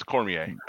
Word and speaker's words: Cormier: It's Cormier.